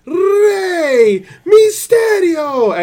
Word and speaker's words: Rey Mysterio: Because Justin Roberts would pronounce it with the accent. – You mean properly? – Yeah, he would announce it, Rey Mysterio!